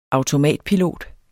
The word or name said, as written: automatpilot